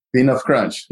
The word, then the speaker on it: P-nuff Crunch: Peanut Crunch. Yeah, that's how you pronounce it.